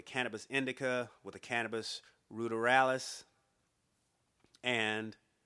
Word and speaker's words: ruderalis: Rud, rudi, yeah, ruderalis. Rud, ruderalis is the best. Is, is that's ruderalis? That's how you pronounce that. Sorry about that.